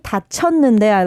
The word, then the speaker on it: 다쳤는데: And especially 일이 다쳤는데, I like how you pronounce 다쳤는데 because that can be really confusing, but you pronounce it really nice.